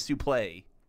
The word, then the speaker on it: suplex: If you go back and watch Gordon Solie, he doesn't pronounce it suplex. He always says suplay.